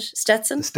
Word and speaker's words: Stetson: I'll get the Stenson. Is that how you pronounce it? Stetson? – The Stetson.